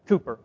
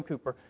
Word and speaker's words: Cowper: I learned earlier this morning in adult education, as we were looking at this hymn by William Cowper, however you pronounce that name, Cooper, okay, it is William Cooper, a fountain, there's a fountain filled with blood, drawn from Emmanuel's veins.